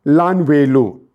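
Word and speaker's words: Lan Wei Lu (烂尾楼): It's called Lan Wei Lu. In Roman, in English, you will spell it as L-A-N-W-E-I-L-O-U.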